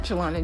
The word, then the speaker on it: Jillana: Mine is at Jillana Jones, and that's spelled J-I-L-L-A-N-A.